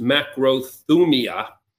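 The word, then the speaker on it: macrothumia (μακροθυμία): The Greek word more or less is pronounced macrothumia, and it's a very long word.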